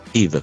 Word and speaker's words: Yves: Ricordiamo, sì, che Yves è scritto Yves con la Y, sì, ma non si pronunciano, sì, mai le ultime due lettere.